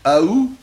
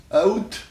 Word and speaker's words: août: Ah, écoutez, il y a en effet les trois prononciations, hein, ou, août, et aou, et même vous entendez, aout. Ah oui, voyez-vous, donc au lieu de trois, comme je disais, ça fait même quatre. Vous pouvez entendre tous, la prononciation correcte, c'est ou, et ou, c'est la prononciation correcte, ou.